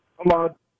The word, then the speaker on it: Ahmad: So how is it? How is it pronounced? – Ahmad. – Like Ahmad. Okay, gotcha.